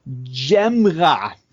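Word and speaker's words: Gyamerah: Ich habe extra heute nochmal geguckt, wie man ihn ausspricht und die Mopo hat es mir vorgeschrieben. Aus Bochum kommt Jan Djemra.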